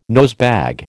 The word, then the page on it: nosebag: American English